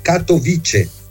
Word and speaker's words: Katowice: Ora la pronuncia esatta è Katowice. – A Katowice? Sì, perché non è una lingua slava come le altre, quindi non ha l'accento sulla terzultima, quindi le parole non sono ossitone, tantomeno sdrucciole, ma sono piane, quindi cade sulla penultima, quindi Katowice.